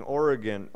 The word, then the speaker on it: Origen: It's interesting, Origen, is actually how you say his name, who was an early church father, made this observation.